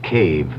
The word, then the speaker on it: cave: For myself, I equate cave with the Latin word spelled the same way, C-A-V-E, but pronounced cave. It means beware.